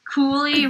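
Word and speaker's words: Cuylle: Cooley rhymes with ghoulie.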